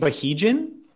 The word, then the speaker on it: Bahija: But like my real name is Bahija, like my God-given, well, actually Allah-given Muslim name that's on my birth certificate. – How do you say it? Bahijan? – Oh, God, no. Bahija.